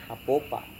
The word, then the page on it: apopa: Serewen